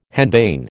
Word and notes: henbane: American English

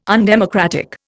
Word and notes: undemocratic: Indian

